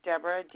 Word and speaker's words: Deborah: My first name is Deborah, D-E-B-O-R-A-H. – Okay, last name? – Chisholm, C-H-I-S-H-O-L-M.